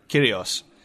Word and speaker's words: Kyrgios: Okay, I was just going to ask for a little bit of background on is it Kyrgios? How do you pronounce the name? – Kyrgios.